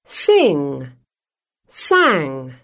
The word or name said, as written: sing-sang